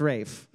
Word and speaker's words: Ralph: We also would have taken the English patient, Ralph Fiennes, as the answer as well, because he says his name is pronounced Rafe. But Rafe.